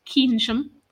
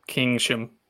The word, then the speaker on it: Keynsham: So continuing that season then, Town are back with their next league game, back at the Gold Star this weekend against Keynsham. We love trying to pronounce some of the team's names here. It's Keynsham. – Keynsham.